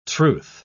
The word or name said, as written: truth